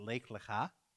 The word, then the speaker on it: Lech Lecha: We're going to pronounce it Lech Lecha.